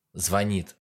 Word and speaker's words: звонит: Да, например, звонит или звонит, друзья. Конечно же, звонит.